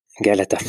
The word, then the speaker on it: Galata: Je ne sais pas si je prononce bien Galatz, c'est bien La Galata.